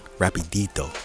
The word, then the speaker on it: rapidito: Rapidito. – Rapidito.